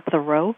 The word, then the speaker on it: Thoreau: First of all, even though most of us say his name Henry David Thoreau, oh, yeah. – Evidence suggests that his last name was really pronounced Thoreau in his time, like you're doing a thorough job. A lot of us have been saying it wrong all these years, so it's hard to get your tongue to say it consistently the right way.